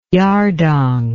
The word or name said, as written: yardang